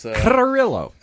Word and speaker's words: Carrillo: Or Carrillo. I never know how to pronounce it. – Those double R's and double L's are always... – Carrillo. – Yeah, there you go. You got to roll your R's, man.